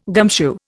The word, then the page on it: gumshoe: Indian